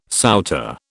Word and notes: souter: British English

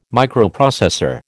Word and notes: Microprocessor: American English

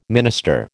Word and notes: minister: American English

